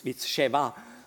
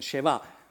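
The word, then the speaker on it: Sheva (שבע): And interesting, again, in Hebrew, number 7, it's Sheva, sounds similar like 7, Sheva.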